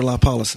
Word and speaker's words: lipolysis: They also have a near laser liposize. Is that how I'll pronounce it? – That's liposize? Right. It's basically laser lipolysis.